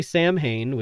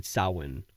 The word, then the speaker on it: Samhain: Some people say Samhain, which is how it's spelled. But from everything I've seen or read, it's Samhain or some sort of variation of that.